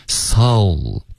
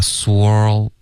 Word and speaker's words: soul: whole soul 不要发 swirl OK 刚刚变成别的发音了是一个双元音啊。